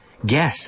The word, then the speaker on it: guess: Guess, adivinar. Gorilla, like the big monkey type animal. Gorilla. Guilt, good, good. So the G-U, G-U-I, G-U-E, guess. In these cases, the U is silent.